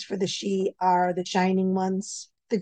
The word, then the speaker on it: sidhe: One of the things was, I guess you said you saw the she, which is spelled S-I-D-H-E, right? – Yes.